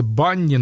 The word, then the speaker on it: BNNY: Там живет девушка по имени Джессика Вишиус, которая вместе со своей сестрой и подругами образовала ансамблик под названием Но я думаю, что это читается Банни, но почему-то буква «Ю» в названии везде пропущена, то есть B-N-N-Y.